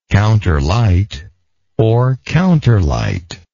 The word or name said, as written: counterlight